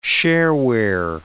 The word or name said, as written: shareware